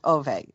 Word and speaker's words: Ove: Okay, I was saying Ove, but it's pronounced Ove in Swedish.